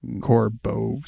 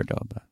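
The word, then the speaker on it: Córdoba: By the way, I can't not correct your pronunciation of Cordoba, because I have family that lives there and it's just my moral duty. – What did I say? – Corbo something or other. – Cordoba. Yeah.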